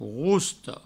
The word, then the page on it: rosta: rousto